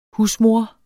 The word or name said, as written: husmor